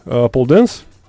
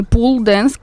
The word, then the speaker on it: pole dance: Давай-то начнем с тебя, потому что, как я понимаю, ты уже много лет преподаешь pole dance. – 2.5 года. – А я правильно его говорю? Он так произносится или? – Pole dance, ни в коем случае не pool dance, как... говорят многие.